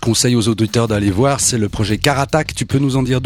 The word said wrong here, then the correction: Carac Attack: deux mots Ouais, il t'a oublié une syllabe. Carac Attack, ouais, c'est une.